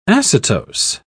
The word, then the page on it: acetose: British English